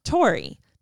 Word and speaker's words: Taury: Tori. The only difference is that my name is spelled T-A-U-R-Y.